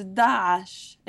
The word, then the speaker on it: Daesh: So it's Daesh. It's hard to say because it has the letter Ayn. The letter Ayn, which we often write in English with using an apostrophe.